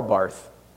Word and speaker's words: Barth: But I'll leave us with a quote by Karl Barth. That's all our seminary friends are going to make fun of me on the recording. It's spelled Barth, but it's pronounced Bart, and I got made fun of a lot in seminary for that.